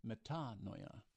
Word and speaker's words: metanoia: That word in the Greek for repentance, metanoia, the accent being on the second syllable, nous in Greek for the noia part, has to do with the mind.